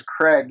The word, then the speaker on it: Craig: My name is Craig.